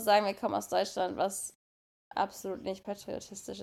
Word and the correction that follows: patriotisch: ist. – Aber. Patriotisch. – Habe ich falsch gesagt? Ja.